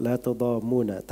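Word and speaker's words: la tudammuna (لا تضامون): Waktu itu saya bacanya dengan tasydid. Beliau bilang la tudammuna tanpa tasydid.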